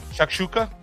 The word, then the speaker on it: Shakshuka: Shakshuka. I hope I said that right. – Shakshuka.